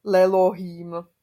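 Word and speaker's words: Lelohím (לאלהים): A když tam k tomu dáme ten lamet, jako komu čemu, lamet jako le, komu čemu, nebo to může být taky jako směrem k něčemu, tak by mělo být le Elohim, jo, ale se nebude vyslovovat, jo, a vznikne z toho Lelohím.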